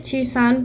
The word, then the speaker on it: Tucson: So my question would be, what do you think is better, Los Angeles or Tucson? – I actually pronounce it Tucson, but it's definitely goes both ways.